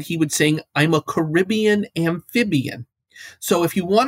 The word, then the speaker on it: Caribbean: Most of those natives do pronounce the word with emphasis on the first and third syllables. So Caribbean is the way to go here.